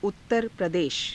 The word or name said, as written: uttara pradesha